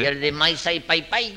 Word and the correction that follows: Mississippi: La I no se pronuncia ahí. – Sí. – Mira, a ver, ahí lo dice, que hay un río que se llama igual, Maisai Pai Pai. – No, señor, Mississippi.